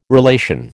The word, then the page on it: relation: American English